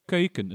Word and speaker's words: Keuken: Also in the Keuken sind wir noch, sorry, wie spricht man, du weißt es besser, wie spricht man es richtig aus? – Keuken, in the Keuken. Ja. Keuken, ja. Oh, das macht man ja gerne, ne?